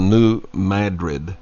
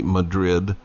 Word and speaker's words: New Madrid: they pronounce it the New Madrid, but it's actually Madrid fault line, which is the Mississippi River.